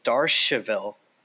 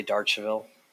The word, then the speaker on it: Darchiville: Okay, so the correct French way to say it is Darcheville. Okay, we just tell everyone to say Darcheville.